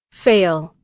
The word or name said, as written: fail